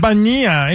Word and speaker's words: Bonilla: Is it Bonilla or Bonilla? – Bonilla. It's Bonilla Day.